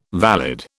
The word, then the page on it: valid: British English